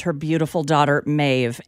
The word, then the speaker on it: Maeve: Maeve.